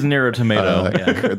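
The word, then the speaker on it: Nier: Automata: It is near a tomato.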